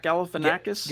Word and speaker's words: Galifianakis: Zach Galifianakis? – Galifianakis, yeah.